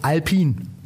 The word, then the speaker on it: Alpine: Die Marke spricht man aus Alpin geschrieben Alpine, aber das E ist stumm.